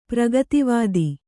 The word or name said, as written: ಪ್ರಗತಿವಾದಿ